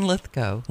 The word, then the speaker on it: Lithgow: Is that how you pronounce his name? – John Lithgow.